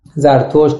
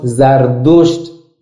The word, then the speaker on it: زرتشت: ابتدای این دین پیامبری بوده به نام زرتشت، بنابر قول ارجح علما زرتشت، زردشت، زردشتره، ده تلفظ داره همین زرتشت.